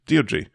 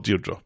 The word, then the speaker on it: Deirdre: Three, this may perhaps be a thing for your other podcast, but Deirdre Barlow's name is Deirdre, not Deirdre. Or if you're Samir, Tier Tree. I know it's pronounced that way in America, but sounds really weird to hear the legendary Mrs. Barlow have her name mispronounced.